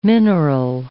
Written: mineral

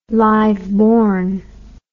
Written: liveborn

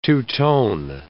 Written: two-tone

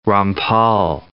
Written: Rampal

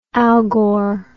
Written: algor

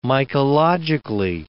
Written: mycologically

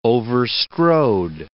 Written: overstrode

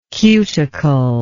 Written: cuticle

